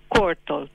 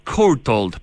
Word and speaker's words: Courtauld: ¿Está bien pronunciado? ¿Courtauld? – Courtauld. – Courtauld, perfecto.